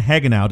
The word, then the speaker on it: Hagenow: We have Dan Hagenow. Did I say that correctly, Dan? – That is correct.